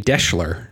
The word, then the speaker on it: Deshler: Tonight, I am uh, drinking a Deshler. It's kind of a variation on a Manhattan, and it a little bit sort of rhymes with Daimler.